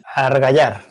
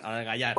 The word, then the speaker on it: argallar: argañar. Creo que lo he dicho bien. – Argallar. – Argallar. – Con, con ella. Ah, vale, vale, vale. Casi, casi, casi me sale.